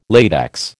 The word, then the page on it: latex: American English